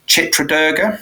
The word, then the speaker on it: Chitradurga: We went to see, now I might be getting the pronunciation wrong, but we went to Chitradurga. Mm-hmm. Is that right? Mm-hmm. To the north. – Yes, that's good.